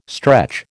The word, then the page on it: stretch: American English